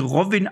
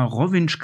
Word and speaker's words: Rovinj: Also das wird Rovinj geschrieben, aber alle Kroaten schwören drauf, das wird Rovinj ausgesprochen und ich glaube, ich habe es die letzten 10 Jahre falsch ausgesprochen, weil ich habe immer Rovinj gesagt oder Rovinj, aber eigentlich heißt das Rovinj.